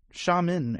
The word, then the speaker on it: Xiamen: Um, Xiamen Airlines, thanks to uh, Stefan for telling me how to pronounce that word.